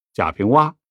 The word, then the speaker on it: 贾平凹: 一般人用的不多，在这儿用了反倒新奇尤其是这个“ 凹”，我们知道它标准的或者官方的读音其实就是读“ 凹”，不过在某些地方方言里，大家也读“ 挖”，比如著名的作家贾平凹就是典型的例子。你按照标准的读法，那得读成贾平凹，这叫名从主人。